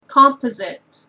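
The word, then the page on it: composite: Canadian English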